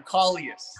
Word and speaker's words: Kaulius: Kaulius.